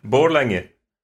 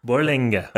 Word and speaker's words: Borlänge: Yeah, Borlänge. Yeah. – Borlänge.